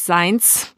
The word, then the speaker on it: Seinz: Seins wird S-E-I-N-Z geschrieben, also so richtig Seins, die hart.